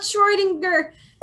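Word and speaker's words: Schrödinger: I know about Schrodinger. Pronunciation. I love Google. Schrodinger. – Congratulations. You have correctly pronounced it.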